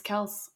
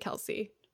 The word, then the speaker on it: Kelce: I didn't know how to pronounce his. It's Kelce. I looked up a TikTok. – It's definitely Kelce.